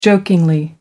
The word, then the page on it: jokingly: American English